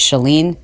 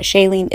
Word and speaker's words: Chalene: It's Chalene, Chalene, Chalene, Chalene, Chalene. It's not Chalene, it's not Charlene, it's Chalene. Like, shut the door.